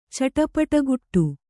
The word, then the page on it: ಚಟಪಟಗುಟ್ಟು: Kannada